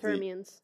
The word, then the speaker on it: Thermians: In Galaxy Quest, I cannot pronounce the name of Thermians.